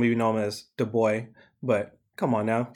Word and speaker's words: Du Bois: We can speak French. It's 2020. It's Du Bois. – Unless you're from Du Bois, PA, which I learned Du Bois, PA does not pronounce it Du Bois, Pennsylvania, but they call it Du Bois.